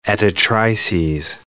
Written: editrices

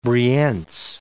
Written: Brienz

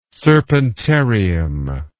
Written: serpentarium

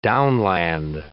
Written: downland

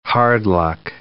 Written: hard-luck

